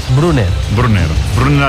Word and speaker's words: Brunner: Paris Brunner, con dos N's, Brunner alemán. – Ahí no tiene problema el Sala en pronunciar, Bruno. – Brunner.